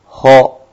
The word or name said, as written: ho